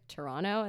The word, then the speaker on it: Toronto: And I was like, Toronto.